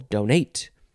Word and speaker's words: donate: You can emphasize either syllable, donate.